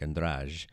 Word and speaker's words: Andrade: Really, she kind of drops that E at the end. It's very subtle, and it's more like Andrade. If you give it a little extra syllable at the end, that's fine.